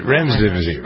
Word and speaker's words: Remdesivir: Remdesivir.